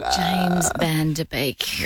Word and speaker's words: Van Der Beek: James Van Der Beek.